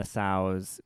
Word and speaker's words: sow: And so what she wants her to save are a sow and the sow's babies, okay? – Mm-hmm. – And she's like, calves. – Nope. Just, I'm getting there. And S-O-W is sow. That's how you pronounce it, right? – Right.